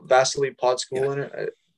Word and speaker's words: Vasily Podkulzin: Vasily Podkulzin? – Yep.